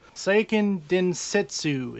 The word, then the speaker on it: Seiken Densetsu: Seiken Densetsu is how it's pronounced, right? – Yeah.